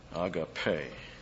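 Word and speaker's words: Agape (ἀγάπη): Agape, long E. Actually, it's almost pronounced as an A, and some guys do transliterate that way.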